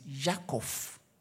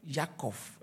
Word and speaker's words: Yaakov: And so when we say Jacob in the Hebrew is Yaakov. Yaakov is not so Abraham, Avraham.